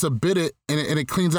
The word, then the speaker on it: bidet: It's, a bidet.